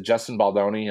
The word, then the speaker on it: Justin Baldoni: is it Justin Baldoni? Am I saying his name? I think I'm saying his name right. – Yes, that's correct.